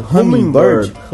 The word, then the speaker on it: Hummingbird: Hummingbird.